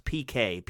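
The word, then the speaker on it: piqué: The shirt, the tie, and the vest are all made of, I think it's pronounced PK, P-I-Q-U-E.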